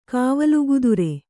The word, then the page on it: ಕಾವಲುಗುದುರೆ: kannada